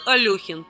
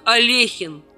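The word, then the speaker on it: Алёхин: Алехин. Правда, такое произношение фамилии Александра Александровича всего лишь широко распространенное и устоявшееся заблуждение, ведь на самом деле его фамилия произносится как Алехин.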